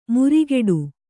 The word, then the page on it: ಮುರಿಗೆಡು: murigeḍu